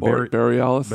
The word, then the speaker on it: Bareilles: Favorite singers, Selena Gomez, Bruno Mars, 21 Pilots, and Sarah Bareilles. I can never pronounce her name. – Bareilles? – Bareilles.